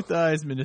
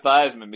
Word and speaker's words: Theismann: Dude, I gotta hand it to Joe Theismann, because the other day I heard him on the radio and he was like, well, at least that guy didn't change his last name to rhyme with Heisman.